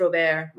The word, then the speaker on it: Robert: His name is Robert. – Oh, Robert? – But pronounced Robert.